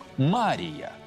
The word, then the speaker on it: Мария: Обычно в этом слове ударение ставится всегда на первый слог – «Мария», ударение на второй слог – и отличает Богородицу от других Марий.